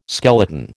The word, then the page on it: skeleton: American English